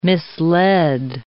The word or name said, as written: misled